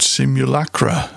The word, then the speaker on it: Simulacra: And then bringing up the rear for that triple, Eric DeMuro from an album called Simulacra, which I absolutely could not pronounce last week, and I've got it right first time this week. The track was called Ara Macau, that's A-R-A space M-A-C-A-O.